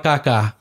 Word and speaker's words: Kaká: For all you people that don't want soccer, there's a player called Kaká. But it's K-A-K-A, right? Different. Accent on the A.